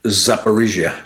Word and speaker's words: Zaporizhia: Zaporizhia. – Zaporizhia.